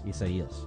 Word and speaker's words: Isaías: I say No, but it's probably the Spanish rendering of it, which if that's the case, then the I is pronounced like a an E. – Isaías.